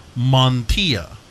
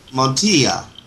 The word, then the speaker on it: Montilla: And a proper Spanish surname, just so you know, you of being Canadian descent, the last name would be pronounced Montilla, with like a Montilla. – Y of the two L's. The two L's you pronounce like a Y, so Montilla. – Montilla.